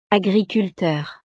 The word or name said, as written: agriculteur